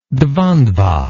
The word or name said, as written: dvandva